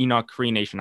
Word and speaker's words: Enoch Cree Nation: So There's one particular episode that I want to highlight, and that's when the show stopped in the Enoch Cree Nation.